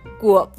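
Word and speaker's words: Kuof (קוף): El arcano del sol está representado por la letra Kuof. Así se pronuncia, pero algunos le conocen como la letra Q, que se fue distorsionando hasta el alfabeto de nuestros días como la letra Q.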